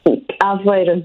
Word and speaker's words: Aveiro: which is Aveiro, right? Aveiro is the name of the town that you're in right now? – Aveiro, yes, Dave.